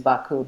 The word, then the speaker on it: Baku: Well, I wouldn't, like, I don't venture the true pronunciation, but so, you know, the like kind of Americanized or anglicized, I guess, pronunciation is Baku, but the way that it's rendered, and it's not a U in Azerbaijani, it's like an I without a dot, and it was just more of like a sound.